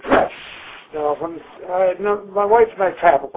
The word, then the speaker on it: Drews: The name, pronounced in Polish, was Dress. And it was spelled D-R-E-W-S. – D-R-E-W-S.